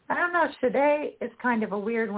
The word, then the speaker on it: Sade: Kool and the Gang, Oasis, Sinead O'Connor, Sade, Sade, how do you say her name? – Sade? Yeah. Mm-hmm.